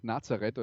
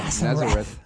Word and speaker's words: Nazareth: Übrigens, ähm, vielleicht ist es euch nicht aufgefallen bei dieser Version, aber es gibt eine sehr bekannte Coverversion der Band Nazareth oder wie sie auf Englisch ausgesprochen werden. Nazareth. Nazareth. Ja.